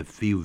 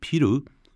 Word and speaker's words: feel: I feel very happy today. Feel. 很多日本朋友啊，他念的这个字啊，念 piru，因为比较发音习惯了 F 发 P，然后 L 发 lu，也蛮爽的这样。